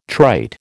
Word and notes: trite: American English

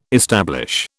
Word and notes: establish: British English

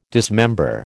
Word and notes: dismember: American English

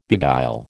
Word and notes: beguile: American English

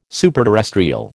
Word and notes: superterrestrial: American English

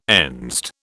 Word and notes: Enzed: British English

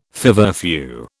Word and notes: feverfew: British English

